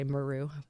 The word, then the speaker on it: Maru: Although he pronounces our last name differently. – Oh my God. Tell me everything. – No, I'm trying to hear it in my head. Mereu is how he says it. – Oh, wow. – Stefano Mereu. Because he's born in Italy. – Okay. – Oh. So he likes to go back to the Italian way of doing it. But I'd rather people be able to pronounce my name easily. So Right. – I just say Maru.